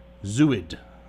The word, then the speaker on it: Zooid: Aside from his own critically acclaimed recording career, Allman has been featured as guitarist and producer in Henry Threadgill's innovative group, Zooid. I am assuming that's how one pronounces that. And has performed... – Yep.